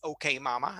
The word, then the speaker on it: Kemama: Förra året så hette låten Kemama som skulle uttalas OK Mama eller Eh, spela på det.